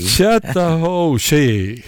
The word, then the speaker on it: Chattahoochee: Alan Jackson, hur uttalar man Chattahoochee? – Chattahoochee. – Jaha, okej.